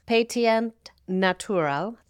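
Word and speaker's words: Pétillant Naturel: P-E-T-N-A-T. In French, the actual pronunciation is Petit Naturel. Thank you to Google Translate for pronouncing that.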